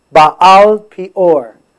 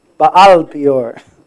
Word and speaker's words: Baal Peor: It's Baal Peor, okay? Not Baal, Baal Peor.